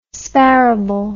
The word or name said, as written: sparable